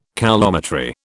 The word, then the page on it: coulometry: British English